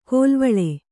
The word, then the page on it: ಕೋಲ್ವಳೆ: kannada; kōlvaḷe